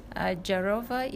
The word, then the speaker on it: Ajarova: I'm on Twitter, on Instagram, on Facebook with the name Lily Ajarova. – Please, can you spell it? – Lily Ajarova is AJAROVA.